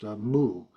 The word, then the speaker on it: mu: In Japanese, the same kanji, the same character, is pronounced mu.